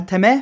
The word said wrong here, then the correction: tatemae: Ah, tatemae.